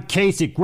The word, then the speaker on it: Kasich: John Kasich, rhymes with basic, from Ohio.